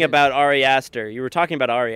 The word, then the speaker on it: Ari Aster: Ari Aster. I wanted to talk to somebody about Ari Aster, but I really couldn't say his name. I'm like, Ad Aster, you know, and I didn't have bars, so I couldn't look his name up.